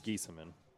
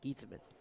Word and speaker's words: Geesaman: Gieseman? – Oh, man. You're pretty good at last names. I give you that. – It is Gieseman. – Gieseman. – G-E-E-S-A-M-A-N. Gieseman.